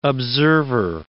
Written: observer

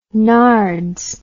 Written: nards

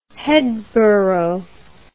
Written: headborough

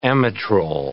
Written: amitrole

